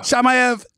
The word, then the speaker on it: Shamaev: Am I pronouncing that right? Yeah. Shamaev. – All right. I'm, oh, I'm, I'm like, yeah, Shamaev.